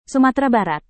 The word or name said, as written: sumatera barat